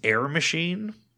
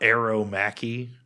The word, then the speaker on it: Air Machi: Air Machi means air machine. I don't know. And it's not Aero Machi. It's Air Machi is how you say it.